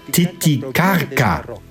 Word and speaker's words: Titicaca: Richtig ausgesprochen, Titicaca. Dieser heilige Ort der Inkas gab dem ganzen See seinen Namen. Die spanischen Eroberer konnten es aber nicht richtig aussprechen und so wurde aus Titicaca Titicaca. Aber korrekt müsste man sagen Titicaca.